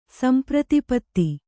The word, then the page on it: ಸಂಪ್ರತಿಪತ್ತಿ: samprati patti